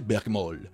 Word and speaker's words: Bergmal: Bergmal, qui est prononcé, ah non, il est marqué plutôt prononcé Bergmol.